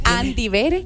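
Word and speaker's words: Andy Vere: Así, está mal escrito, ¿eh? Bere no se escribe con V, pero yo lo escribo así, entonces así lo buscan, Andy Bere.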